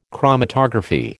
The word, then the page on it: chromatography: American English